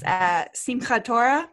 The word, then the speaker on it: Simchat Torah: Okay, I can't say it right, but it's Simchat Torah, where they unroll the whole. – Really well with the chah and everything.